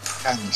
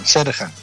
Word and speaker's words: Sherhan: Sherhan. Sherhan. Sherhan. Se pronuncia como si fuera J.